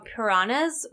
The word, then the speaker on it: Puranas: And they also revere what's called Puranas, which it sounds like I'm saying like the fish piranha, but it's spelled differently. P-U-R-A-N-A-S. Puranas.